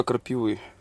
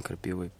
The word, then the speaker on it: крапивы: А это листва крапивы. – А мне показалось дубовые листья. – Нет. – А почему нас... крапива? Почему? – Крапивы, да, правильно говорить. – Почему? – А не крапивы.